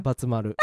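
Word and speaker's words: Badtz-Maru: How do you say it in Japanese, Batsumaru? – Batsumaru.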